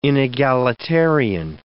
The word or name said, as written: inegalitarian